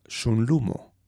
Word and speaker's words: sunlumo: Por ejemplo, tomemos la palabra sunlumo, con acento tónico sobre la penúltima sílaba, lu.